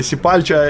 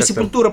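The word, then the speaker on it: Sepultura: Или Сепальча, или... – Ну, Сепультура, правильно, португальский язык в Бразилии. Сепультура. – Что, то есть такое произношение? – У нас... – У нас... Это американизированное, неправильное произношение.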